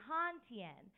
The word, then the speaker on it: Hanh Tien: My name is Tien, but my full name is actually Han Tien, which is spelled H-A-N-H-T-I-E-N.